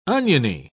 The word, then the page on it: oniony: British English